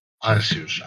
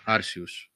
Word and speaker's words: Arceus: Λοιπόν, ε, στις 28 του μήνα βγαίνει το ε, Αρκέους, Αρκέους, Αρσέους, πώς το προφέρουμε αυτό. – Άρσιους, Άρσιους. οκ. Okay.